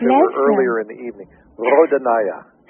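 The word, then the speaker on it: Rodonaia: And I remember seeing George, and I'm never sure how to pronounce his name, Rodonian? – The Russian. – Yes. – Rodonaya. – Rodonaya, there we go.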